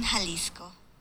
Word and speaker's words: Jalisco: They have sent me a clip, which I will play now to indicate to the group the correct pronunciation of the word. – I love to drink Jarritos in Jalisco.